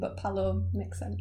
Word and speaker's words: Pallot: So, Bill, B-I-L-L. – Got that. – A G, then a B, and then Palot, P-A-L-L-O-T. But I think I'm going to pronounce it Palo.